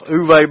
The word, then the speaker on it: Uwe: The other thing was how to properly pronounce Uwe Boll's name.